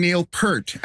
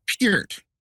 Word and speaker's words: Peart: I've been saying Neil Peart, as in pearl. – Yeah. – It's Peart.